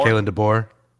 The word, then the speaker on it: DeBoer: I always mispronounce the coach's name. DeBauer? – Kalen DeBoer. – DeBoer, thank you.